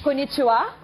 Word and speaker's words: Konnichiwa: Konnichiwa? – Konnichiwa. – Eu falei certo? – Exatamente.